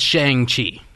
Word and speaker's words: Shang-Chi: It's Shang-Chi. – Shang-Chi? – Yeah.